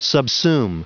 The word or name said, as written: subsume